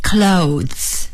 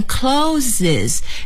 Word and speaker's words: clothes: 衣服 clothes, clothes, clothes。请朋友们一定要注意，好多朋友呢看到这个 clothes 是以 es 结尾的，就念成 closes，这是错误的，非常错误，非常错误，千万别学刚才说的那个啊。